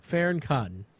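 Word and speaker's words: Fearne Cotton: Farron Cotton. I'm saying it right. – Right. They say it like Farron.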